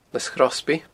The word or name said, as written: Lios Chrosby